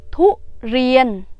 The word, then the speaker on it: ทุเรียน: tiếng thái đọc là thú riêng thú riêng thú riêng cái từ thú á,